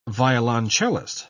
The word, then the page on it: violoncellist: British English